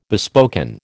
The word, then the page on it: bespoken: American English